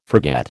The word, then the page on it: forget: American English